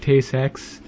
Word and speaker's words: Tay-Sachs: Tay-Sachs. I think it's pronounced.